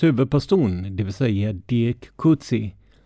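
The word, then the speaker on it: Coetzee: Nu har två lyssnare skickat information om det faktiska uttalet och tydligen ska den första stavelsen uttalas ungefär som i engelskans book, medan den sista stavelsen ska uttalas som sista stavelsen i idé. Alltså bör det bli kutsia eller liknande.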